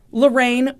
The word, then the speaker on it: Larene: Lorraine, which I will tell you, I did not think was pronounced that way, but that's how they pronounce it on the Dateline episode. It is spelled L-A-R-E-N-E. – Say that again. – L-A-R-E-N-E.